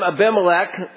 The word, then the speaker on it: Abimelech: At that time, Abimelech, and that is the correct way of pronouncing it.